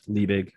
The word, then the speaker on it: Liebig: I think it's Liebig. I'm probably mispronouncing that. Law of minimums. – All the OGs, you pronounced it correctly.